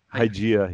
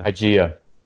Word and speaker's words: Hygieia: Is that Hygieia? – Hygieia, yeah. – Hygieia. – Like hygiene.